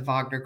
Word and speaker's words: Wagner: So this, just pronounce it again, because W-A-G-N-E-R, but how is it pronounced? – It's the Wagner Group. Some people call it the Wagner Group. – But that's the Americanized version.